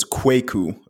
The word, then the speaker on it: Kwaku: You pronounce the A a bit more, so it's Kwaku.